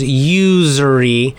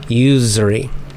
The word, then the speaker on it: usury: So American pronunciation is usury. So it's got that z. It actually is like a z sound, z, that buzzing z, right? Not usury with an s, soft s, is not American. It's usury. We have that. We get that little z for the proper pronunciation, usury.